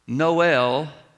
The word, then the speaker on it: Noel: So he arranged for this city in Missouri that was rhyming with mole to be called Noel, the Christmas City.